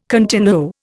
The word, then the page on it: continuo: Indian